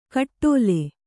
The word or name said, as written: ಕಟ್ಟೋಲೆ